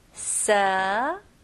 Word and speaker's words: severe: 네, 여기서는 V 발음 해주셔야 돼요. 그래서 입술이 붙으면 안 되고 제 입모양을 보세요. Severe.